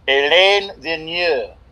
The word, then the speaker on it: Alain Vigneault: Alain Vigneault.